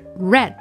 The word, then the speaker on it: read: 的过去分词，那在读音上呢，它是有变化的，所以这里面我们读的是 read read。